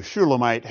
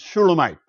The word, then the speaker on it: Shulamite: That name, too, Shulamite, has the same Hebrew consonants as the name Solomon, with different vowels, however. So we pronounce it a little bit differently, but you can hear the resonance of Solomon with Shulamite, perhaps.